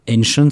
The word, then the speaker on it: Ancient: C'était Commands and Colors ancien, enfin Ancient, si je veux le prononcer correctement, qui relate les batailles entre les Romains et les Carthaginois, donc dans l'Antiquité.